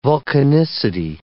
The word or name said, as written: vulcanicity